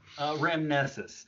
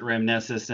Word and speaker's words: Ramnessus: Ramnessus. You're not wrong in where it's supposed to come from, but I've always pronounced it Ramnessus, and on our own podcast, I always say it as Ramnessus.